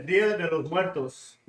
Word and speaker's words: Día de los Muertos: El Día de los Muertos.